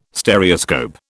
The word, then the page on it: Stereoscope: British English